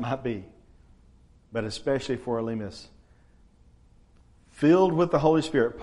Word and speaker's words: Elymas: Now, just imagine, even if you're not Elymas, by the way, I think the Greek pronunciation of that is Illumis or something. I'm going to quit trying to pick those other pronunciations out. We'll just go with the normal English pronunciations.